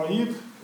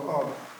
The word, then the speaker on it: Аид: Вечной, конечно, до тех пор, пока Христос не разрушил ее и не связал этого ада. Пишется это слово, там буква А, потом греческая «иота», но она подписная, и она не читается. Поэтому два произношения есть. Классическое «аид» и церковное «ад», без этой «иоты».